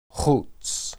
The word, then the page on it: huts: hebrew